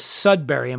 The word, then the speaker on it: Sudbury: Are you familiar with Sudbury? Am I saying that correctly? – Yes, you are.